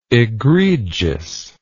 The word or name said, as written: egregiousness